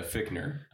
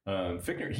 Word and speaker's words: Fichtner: Yeah, Fichtner. – Fichtner? – Yeah, it's spelled Fichtner, but yeah. Fichtner, he fucking rules.